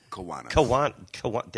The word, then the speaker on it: Kiwanis: Kiwanis? Am I pronouncing it? – Kiwanis.